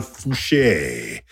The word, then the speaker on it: Fouché: And of course, we've got Professor Raymond Fouché. That's an E with an accent, in case you didn't know how to pronounce the trailing E.